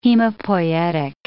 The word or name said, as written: hemopoietic